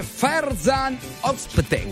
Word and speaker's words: Ferzan Özpetek: Il libro si intitola Cuore nascosto, diciamolo, del regista e scrittore Ferzan Özpetek.